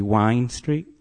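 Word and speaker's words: Wind Street: Actually, it's spelt wind, but it's pronounced locally Wine Street.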